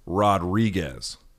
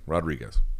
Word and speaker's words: Rodriguez: So if you grew up speaking English and you see this word, the way you would pronounce it is Rodriguez, right? Most people, Rodriguez, R O D R I G U E Z, Rodriguez. But the way it was taught to me in Espanol is Rodriguez.